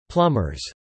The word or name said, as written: plumbers